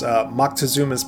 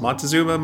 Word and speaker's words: Moctezuma: And then the final movie was Moctezuma's Palace. So it depicts Moctezuma, who I think we would probably mispronounce as Montezuma most of the time.